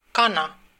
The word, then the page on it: woman: /ˈwʊm.ən/